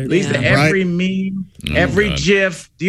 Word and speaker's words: GIF: But he stated categorically in an interview more than a decade ago, it is definitely pronounced GIF.